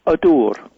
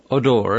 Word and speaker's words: Ydwr: Now, the title of your novel is, how would you pronounce it? – Odur. – Odur, and it's spelled Y-D-W-R, right? – That's right.